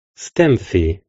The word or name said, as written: STEMFIE®